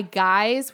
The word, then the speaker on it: Guize: So it's this kit by Guise, which is spelled like G-U-I-Z-E.